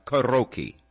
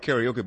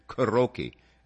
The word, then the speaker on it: karaoke: Well, properly pronounced karaoke. Okay. We have Americanized the word and call it karaoke, but it's actually karaoke.